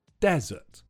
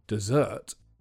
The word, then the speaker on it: desert: The only thing to be careful about with this word is the stress. It's on the first syllable, desert. If you say the word with the stress on the second syllable, desert, then it means the sweet course you sometimes eat at the end of a meal.